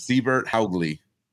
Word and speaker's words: Sivert Haugli: So we'll be talking about, I'm sorry if I butcher it the first few times, Siebert Haugli. How'd I do, Owen? – Yeah, that's pretty much what he told us it was today.